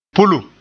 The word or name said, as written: pulum